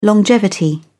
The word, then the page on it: longevity: British English